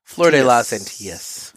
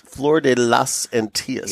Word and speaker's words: Flor de las Antillas: I always butcher this. The Flor del Flor de las Antillas. – Flor de las Antillas. – Yes.